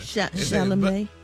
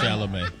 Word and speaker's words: Chalamet: Chalamet. – Oh, that's how you say it. – Yeah, Chalamet.